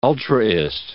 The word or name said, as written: ultraist